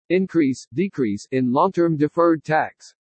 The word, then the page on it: INCREASE DECREASE IN LONG-TERM DEFERRED TAX: english